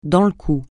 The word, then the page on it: dans le coup: francais